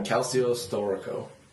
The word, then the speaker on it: Calcio Storico: It's called, and I apologize if I fuck up on the pronunciation, Calcio Storico. What do you think it's pronounced like? Calcio? – Yeah, Calcio Storico.